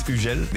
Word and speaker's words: Hugel: In French, it's Hugel. The English going like Hugo, you know. Then I have the German going like Man Hugel.